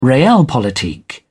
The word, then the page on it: realpolitik: British English